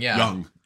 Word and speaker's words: Jung: Jung.